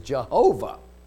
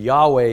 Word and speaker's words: Yahweh: but there was another pronunciation of Yahweh, and that is Jehovah. But Jehovah, which is a very familiar name to us, we read it still in the Psalms and in other literature, and many of our hymns use the word Jehovah. Now we really understand that that's not the proper pronunciation or the best use of the title. And Yahweh is really what we're after.